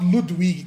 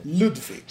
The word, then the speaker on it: Ludwig: finalmente, pensei, pensei que você ia falar Ludwig, cara. Eu ia te xingar tanto se você falasse isso. Depois você pronunciou correto o nome dele em alemão. Ludwig.